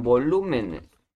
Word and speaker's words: volúmenes: Ah, bueno, no vamos a ponerle eh, el balance de exergía en volúmenes. de control se expresa como la pasa de cambio de energía. – Volúmenes, llevaba acento en la U, no en la O.